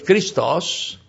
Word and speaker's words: Christos: The word Christ is pronounced in the Greek language Christos, and is the word that corresponds to the translation of the word Messiah of the Old Testament.